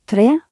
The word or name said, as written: 3